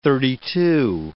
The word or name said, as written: thirty-two